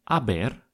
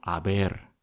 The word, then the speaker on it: haber: Сначала мы берем глагол «haber» и ставим его в нужную форму. Все эти формы будут начинаться с буквы «h», которую, как нам уже известно, мы никогда не произносим.